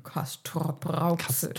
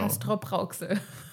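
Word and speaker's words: Castrop-Rauxel: Ähm, Kastrop Rauxel. Kastrop Rauxel. Kastrop. Ach, Kastrop. Kastrop. – Oh Gott. Ich habe hab immer Castor gesagt, wie der Castor-Transporter. – Kastrop Rauxel.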